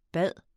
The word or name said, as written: bede